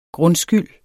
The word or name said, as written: grundskyld